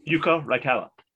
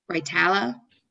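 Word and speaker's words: Jukka Raitala: Yuka Raitala. – Raitala? – Yep.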